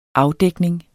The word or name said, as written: afdækning